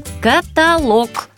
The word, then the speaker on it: каталог: Как правильно, каталог или каталог, спрашивают нас? Правильно, запоминаем каталог. Ударение на последнем слоге.